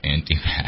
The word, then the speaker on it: Antifa: And I think we should reclaim the pronunciation of Antifa. I hate that pronunciation. It should be Antifa. – Antifa. – Antifa. – The emphasis should be on the ah.